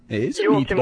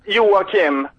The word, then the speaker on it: Joakim: Well, you know, Joakim Noah in Chicago Bulls. – You like us in the Chicago Bulls? – No, I'm saying you pronounced it pretty good. Joakim, you can say.